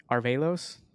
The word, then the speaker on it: Arevalos: Joining me today is the co-founder and CEO at Starling Medical, Alex Arevalos.